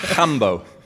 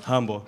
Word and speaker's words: Rambo: It's more like Hambo, I've learned. Hambo? – Hambo. – There you go. – Yeah. – Even though it's like Guy Rambo, right? But it's Hambo.